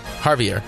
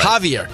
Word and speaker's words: Javier: You can't say it. Try it again. – Christian Javier. – No, you're not saying it right. – Javier. – There you go.